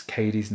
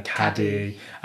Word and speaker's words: Cady: One of the running jokes of this film is that no one knows how to pronounce Cady's name, which, so everyone keeps calling her Caddy.